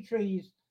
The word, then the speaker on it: trai: But the Three Trees, that's a lovely barred picture for that. It's spelled T-R-A-I, pronounced tree.